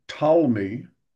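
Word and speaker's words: Ptolemy: which was forced upon us by Talmi, with a silent P before his name, like pterodactyl.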